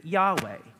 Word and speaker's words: Yahweh: And those four letters in Hebrew are YHWH. We pronounce it Yahweh.